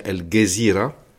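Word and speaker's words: Al Jazeera: contre la chaîne El Jazeera, qu'on prononce en arabe égyptien El Gezira, parce qu'on prononce le J G en Égypte,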